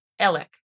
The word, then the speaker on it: Alex: Now it's spelled A-L-E-X, so you would think it's pronounced Alex, but it is not. It is pronounced Ellick.